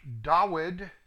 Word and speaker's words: David: And it begins with a story about David, which is pronounced Dawid, and Goliath, which is pronounced Goliath, correctly.